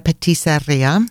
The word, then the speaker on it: patisserie: Regarding the patisserie, this is the better pronunciation, Tommaso. You can work on this.